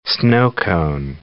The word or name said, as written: sno-cone